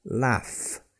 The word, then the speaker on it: laugh: And then finally, we have another F sound with the double consonants G and H at the end of a word like laugh. Hear that? You get that F sound even though the word is spelled L-A-U-G-H.